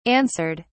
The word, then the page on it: answered: British English